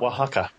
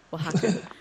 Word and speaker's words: Oaxaca: And uh, we want to talk to you about Mexico, especially Oaxaca. – Sure. – I think is it, I think that's how you say it. In fact, I have no Oaxaca. – Oaxaca.